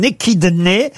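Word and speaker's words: échidné: Il s'agit d'un équidné. Ça s'écrit E-C-H-I-D-N et l'équidné.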